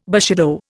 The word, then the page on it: bushido: Indian